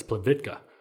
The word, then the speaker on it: Plitvice: I would have missed uh, Plavitka, which I was pronouncing Plitvice for the longest time because I was just going off of how Americans spell it. It's spelled it's spelled like Plitvice, but over there it's Plitvitka.